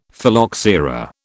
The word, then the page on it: Phylloxera: British English